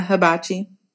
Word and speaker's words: hibachi: He didn't, because even the day of, like, when I was driving down there, he had told me he wanted to do hibachi. Is that how you say it? – Yeah, hibachi.